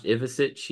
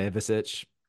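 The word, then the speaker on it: Ivišić: Last name is pronounced Ivicic. Yeah, it's like Ivicic.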